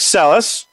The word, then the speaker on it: Sellas: Speaking of QuickBooks Online or not, let's bring on our incredibly wonderfully specially spectacular guest for the day, Brooke Sellas.